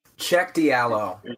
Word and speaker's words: Cheick Diallo: Sheik Cheick Diallo.